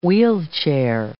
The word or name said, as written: wheelchair